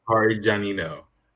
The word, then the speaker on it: Parmigianino: Parmigianino.